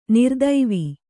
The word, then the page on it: ನರತ್ವ: naratva